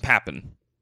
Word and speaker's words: Papen: P-A-P-E-N. How do you think that should be pronounced? I'm going to go with Papen.